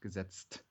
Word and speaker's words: Gesetzt: Like you really need to pronounce this last T, like walk the last mile and pronounce it. So don't be lazy. – Yeah. – Pronounce the last T to really get the TZT out with Gesetzt.